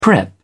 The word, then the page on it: prep: British English